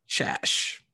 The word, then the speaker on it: Szasz: it is pronounced Shash. – Shash. – Yeah, you Shash. got it. Shash. Shash. Shash. Heather Shash.